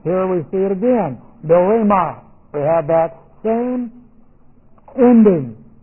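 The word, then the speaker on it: do-re-ma (δώρημα): Now we have do-re-ma. Now watch this. Here's what we have. Do-re-ma. That's D-O-R, long E, M-A.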